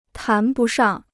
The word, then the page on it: 谈不上: tán bu shàng